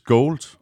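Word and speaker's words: Gould: Det udtales gold. Nå ja, Rob, kæft, hvor er langt Men det staves gold.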